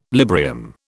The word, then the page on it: Librium: British English